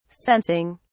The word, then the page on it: sensing: British English